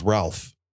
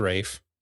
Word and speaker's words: Ralph: His name is Ralph. – His name is Rafe. – It's Ralph. – It's spelled Rafe. Ralph, but it's Rafe. – It's pronounced Rafe.